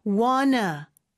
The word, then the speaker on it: wanna: 그래서 이때는 뒤에 것만 발음해 주시면 되는데 N 다음에 오는 T는 또 생략이 가능해요. 그래서 wanna 이렇게도 발음할 수 있어요. What do you want to? T 한번 발음 혹은 What do you wanna? 까지도 됩니다.